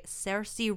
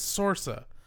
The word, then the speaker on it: Saoirse: And all the while, he is also romancing a girl named Agatha, played by Saoirse Ronan. – Is that how you say her name? Mm-hmm. I always thought it was Sorsa, but I don't think I've ever heard anyone say it until today.